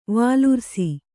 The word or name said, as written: ವಾಲುರ್ಸಿ